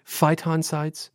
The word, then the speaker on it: phytoncides: At one point on this journey, you breathe in, am I saying this right, phytoncides? – Yes.